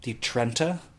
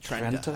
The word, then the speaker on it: Trenta: It was the Trenta. Would that be the correct pronunciation? – Trenta? I think that sounds right. That sounds okay. Oh, I like how you rolled the R there.